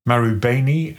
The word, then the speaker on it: Marubeni: Okay, so it's Mitsui, Itochu, Marubeni and Sumitomo.